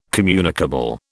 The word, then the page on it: communicable: British English